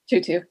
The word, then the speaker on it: Tutu: I hope I said that right. Tutu? – Tutu, yeah.